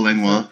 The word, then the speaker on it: Langlois: How do you say his name? Yeah, yep. Paul Langlois. – Perfect, yeah.